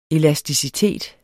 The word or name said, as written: elasticitet